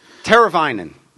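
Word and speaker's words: Teräväinen: Something that ends in S-S-O-N. I can't even pronounce it. – Teravainen. – That's Teravainen.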